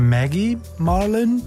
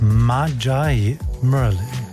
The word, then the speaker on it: Magi Merlin: Vorname schreibt sich so wie eine bekannte Würzsoße namens Maggie, nur mit einem G. Ist das jetzt eine Maggie Marlin? Nein, nein. Diese hier, die spricht sich aus Magi Merlin.